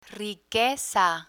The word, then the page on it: riqueza: spanish